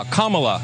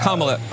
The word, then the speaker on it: Kamala: Her name is pronounced comma like the punctuation mark, la, Kamala.